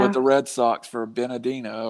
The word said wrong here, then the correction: Benintendi: or whatever you say. – Benintendi.